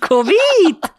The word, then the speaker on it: Covid: Covid! – Gar nicht wissen, wie man das ausspricht nach Covid. Covid. Ganz albanisch ausgesprochen.